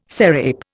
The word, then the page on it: serape: Indian